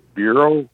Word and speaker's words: Burau: It's pronounced Bureau, just like Federal Bureau of Investigations, but it's spelled B-U-R-A-U.